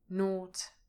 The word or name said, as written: nought